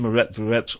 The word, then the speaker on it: Myrotvorets: For his part, Shary has been on the notorious Mirot Verets, one more time, that's spelled, by the way, M-Y-R-O-T-V-O-R-E-T-S, and those are Anton Gerashenko and Mirot Verets, or prop or not.